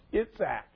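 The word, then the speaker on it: Yitzhak: and you shall call his name Isaac, which is pronounced Itzak.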